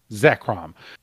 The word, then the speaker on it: Zekrom: You have Kyurem and what was how do you pronounce the other Zekrom. One's name? Zekrom.